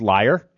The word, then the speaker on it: Leier: Actually, if you looked at my name, it's pronounced liar. – Yeah. – L-E-I-E-R, like Meyer.